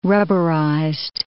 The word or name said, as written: rubberized